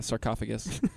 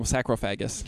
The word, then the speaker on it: sarcophagus: Yeah, Tom Cruise was in a sarcophagus. Which, when I was a kid, I was obsessed with this stuff, and I used to call him Sacrophagus. Because I didn't know how to pronounce it.